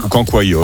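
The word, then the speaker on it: cancoillotte: Le cancoyote. – Oui. – Ça se prononce comme ça? – Oui, ça se prononce comme ça. – C'est-à-dire comme ça? – Oui, oui. – Cancoyote.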